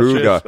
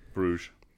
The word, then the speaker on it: Brugge: I'm not conforming to that Brugge. – Shit. – Then pronounce Club Brugge correctly. – Bruges. – Yeah, that's not how it's said.